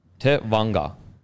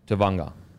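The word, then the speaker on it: Tevaga: It is actually pronounced. I messaged him. I was like, sorry, bro. I think I've been saying your name wrong. He's like, I don't give a fuck. so I was like, what are you messaging me for? So Tevanga. Tevanga. So T-E-VUN-R. Tevanga. So Jazz Tevanga.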